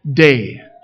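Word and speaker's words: day (δεῖ): Now the Greek word that gets translated must there, it's in your insert, it's the little word, it's pronounced day, just like Sunday.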